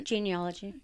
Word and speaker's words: genealogy: So I've been doing genealogy. Did I say it right? – Genealogy. – Yes.